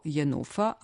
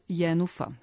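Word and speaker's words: Jenůfa: počev od samog imena glavne junakinje koje se ponekad javlja kao Jenufa, a ponekad Jenufa, što je pravilnije.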